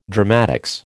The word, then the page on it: dramatics: American English